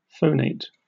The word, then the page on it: phonate: American English